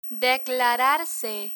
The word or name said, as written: declararse